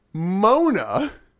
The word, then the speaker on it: Mona: So Medusa then interrupts with Mona. – Mona. – I'm really wanting to believe it's spelled M-O-N-A and not It's definitely M-O. It's definitely spelled the way Moana is spelled, but pronounced differently.